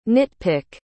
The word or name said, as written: nitpick